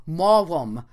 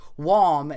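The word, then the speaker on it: Maugham: He said they were discussing the proper pronunciation of mom, and it said, the name is pronounced mom, declared one who had been in London. No, it's mogum, opined someone else. Mom is better. Why not mogum, said another. And they all started just arguing. And finally, the one who started it said, mum's the word. I'm sorry, I just loved that. Mom himself said his name rhymes with wom, as in it's a wom day.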